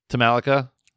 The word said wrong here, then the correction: Talokan: Talokan. – Yeah. Talokan.